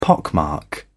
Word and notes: pockmark: British English

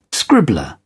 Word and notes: scribbler: British English

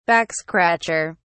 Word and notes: backscratcher: British English

